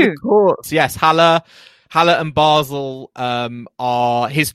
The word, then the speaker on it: Basel: How do I say it? Basel. – Basel is correct.